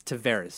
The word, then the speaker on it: Tavares: Is it Tavares or Tavares? I forget now. There's a whole thing on Leafs Twitter today about that. I think it's Tavares is the way he pronounces it. – I say Tavares. – I also say Tavares. So I think the actual way to pronounce it is it's Tavares.